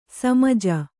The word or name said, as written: ಸಮಜ